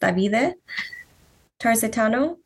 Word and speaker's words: Davide Tarzitano: And it is similar to where it's like based off of Hollow by, I think he said it's pronounced Davide Tarzitano.